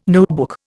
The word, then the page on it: notebook: Indian